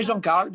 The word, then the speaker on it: Jean Carles: So this is from perfumer I hope I say his name right, Jean Carles. – Yeah, yeah, Jean Carles.